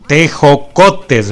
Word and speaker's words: tejocotes: Pues mira, esta receta requiere de algunos ingredientes tradicionales como la canela, los tecojotes. Sí lo dije bien, tejocotes, ¿verdad? Tejocotes. Está perfecto esto, ¿verdad?